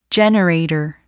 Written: generator

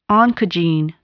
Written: oncogene

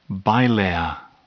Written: bilayer

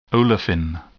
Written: olefin

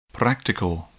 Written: practical

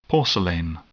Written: porcelain

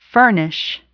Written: furnish

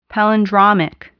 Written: palindromic

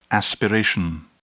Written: aspiration

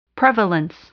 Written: prevalence

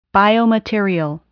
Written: biomaterial

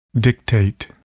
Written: dictate

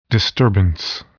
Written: disturbance

